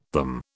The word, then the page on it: them: British English